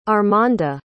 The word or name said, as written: armanda